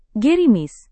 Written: gerimis

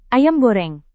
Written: ayam goreng

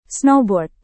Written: snowboard

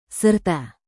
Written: serta

